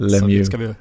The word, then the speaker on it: Lemieux: Jag tänker ju Pittsburgh, men så jag hade faktiskt inte koll på att det var Montreal som var hemstaden, men Lemieux, ja, hur uttalar man det på franska? – Han sa ju faktiskt själv Mario Lemieux om sig själv, så Lemieux.